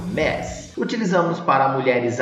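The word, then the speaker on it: Ms: E repare que a pronúncia que eu fiz aí ó, sai com o som do E entre o M e o S, tá? Um MES. Vamos para um exemplo?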